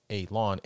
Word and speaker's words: Alon: So, in 1954, Daniel Fry published a book called The White Sands Incident, which described his abduction by a humanish-looking alien called Alon, pronounced Alon, A-L-A-W-N. – Not A-A-Ron? – Not A-A-Ron.